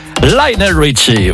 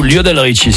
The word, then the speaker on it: Lionel Richie: 4 à la suite, Lionel Richie ou Lionel Richie, si vous voulez prononcer à la française, il n'y a pas de problème.